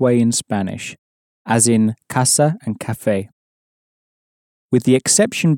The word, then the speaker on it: casa: It's worth noting at this point that the C in casa is pronounced as a hard C, like the C at the beginning of the English word car.